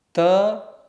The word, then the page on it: t: sanskrit